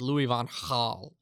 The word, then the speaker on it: Louis van Gaal: Yeah, I think it's like, I'm pretty sure you have to like way over pronounce it to like get it. It's like Louis van Gaal is like how he likes it pronounced.